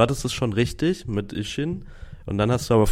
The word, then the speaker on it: Ishin: Ich muss mich noch entschuldigen, dass ich letzte Folge das neue Yakuza-Spiel falsch ausgesprochen habe, denn es heißt tatsächlich Like a Dragon. Ach so, Ishin. Ishin. Ja. Ich glaube, ich habe Kenshin gesagt.